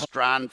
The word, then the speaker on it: Strachan: So I immediately looked at that landscape of data and people were thinking Michael Strahan, S-T-R-A-C-H-A-N, pronounced Strahan for some reason, or Ashton Doolin or Paris Campbell to the moon.